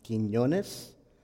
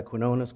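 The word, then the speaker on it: Quiñones: My name is Carlos Quinones. If you've ever taken a Spanish class, there's an N over the first N. It's pronounced Quinones. But that's okay. I'll go by Quinones, Carlos, whatever you want to call me.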